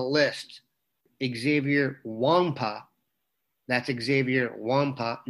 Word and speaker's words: Nwankpa: Nwankpa, the N and the K, are silent, so we will say his name correctly for the rest of the time we cover him finally. Xavier Nwankpa, the number one safety in the top 247.